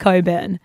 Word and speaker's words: Cockburn: There's a suburb in Perth that's Cockburn, except it's pronounced Coburn. – No, it's not. – It is. I swear to God. – Wait, it's spelled C-O-C-K. – Yeah. – Yet they say Coburn.